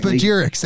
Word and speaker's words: spagyrics: Uh, we can move forward into uh, I believe I pronounced that correctly. Yes, Spagyrics.